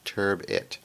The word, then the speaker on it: turbot: Turbot. It's pronounced turbit.